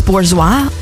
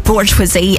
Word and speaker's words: Bourgeois: But now we've got the Throttle House guys alongside Francis Bourgeois, which I think is how you pronounce his second name. I keep going to say Bourgeoisie, and that's definitely not it, but it's spelt like that.